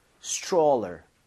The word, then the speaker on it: stroller: 읽을 때 여기 tr mom 이렇게 되면 얘가 t하고 r을 충돌을 스트롤러. – 하면서 치읓 비슷해집니다. 그래서 스트롤러가 아니라 입 모양을 좀 모으면서 stroller 이렇게 해서 발음을 해보는 거예요. 두번 갑니다.